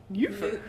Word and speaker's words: Neupher: Her name is Jean Neufer. Neufer? Neufer. – You want to spell it? – N-E-U-P-H-E-R. Neufer. – Neufer. – Neufer. – Like nephew? Neufer? – Sure, I'll take your word for it.